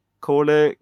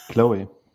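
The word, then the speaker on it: Chloë: Och i den här tv-serien så är ju hon, vet hon, Cole Grace Moretz. Jag vet inte hur man uttalar det första. Men hon som är med i Kick-Ass. – Chloe.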